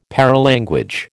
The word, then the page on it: paralanguage: American English